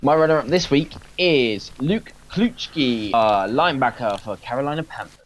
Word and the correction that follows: Kuechly: Luke Kuechly, just to correct Luke you there, buddy.